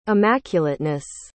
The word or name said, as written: immaculateness